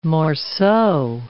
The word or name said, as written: morceau